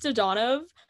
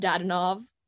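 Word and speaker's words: Dadonov: His last name is pronounced Donov. I know this because he went 8 years into his NHL career before correcting anyone. Everyone's always called him Dadanov.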